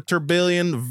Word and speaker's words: Tourbillon: You're really pronouncing Tourbillon. That really well. – Tourbillon.